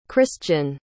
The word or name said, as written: khrystian